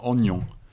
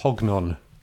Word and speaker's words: Hognon: But he still is in charge, technically, with Vincent Ognon. I hope you like that pronunciation. – I think that's very good. Yeah, thanks. – Hognon. – Silent H. – Vincent Hognon.